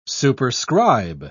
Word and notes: superscribe: British English

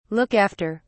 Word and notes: look after: British English

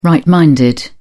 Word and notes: right minded: British English

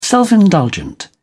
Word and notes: self-indulgent: British English

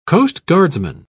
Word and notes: coastguardsman: British English

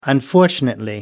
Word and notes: unfortunately: American English

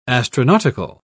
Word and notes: astronautical: British English

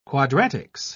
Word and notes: quadratics: British English

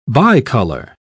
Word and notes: bicolor: British English

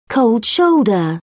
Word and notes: cold shoulder: British English

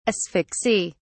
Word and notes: asphyxy: British English

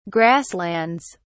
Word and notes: grasslands: British English

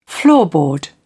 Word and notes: floorboard: British English

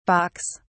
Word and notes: balks: British English

